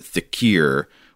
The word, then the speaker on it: dhikr: So the word here is spelled D-H-I-K-R or D-H-I-K-I-R. I've heard it pronounced thicker before, but Sean gives a pronunciation guide in which he says to pronounce it thickier, which might be like a regional language variation. I'm not sure. But since Sean gives that, I'm going to say thickier instead of thicker for the rest of the email.